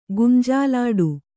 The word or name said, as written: ಗುಂಜಾಲಾಡು